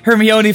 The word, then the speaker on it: Hermione: Hermione? – Okay, that technically counts because it is how it's written. – That's how I always pronounced it until I watched the first movie.